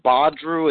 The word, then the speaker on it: Badru: It's uh, Badru Akeem Gardner.